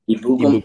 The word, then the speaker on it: Ebukam: Ebukam is an absolute monster.